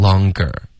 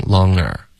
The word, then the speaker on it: longer: 有两种发音，一个是一个歌的音 longer，然后这个比较常见。可是有时候说快的时候呢，嗯，这个歌的音就听不见了，就直接用一个鼻音 longer，就有一个嗯的音直接连过去。这两种发音都会见到。